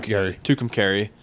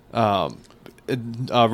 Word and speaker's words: Tucumcari: Tucumcari. – Tucumcari.